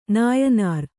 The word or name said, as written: ನಾಯಿನಾರ್